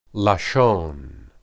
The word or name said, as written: lashon